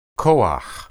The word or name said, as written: koah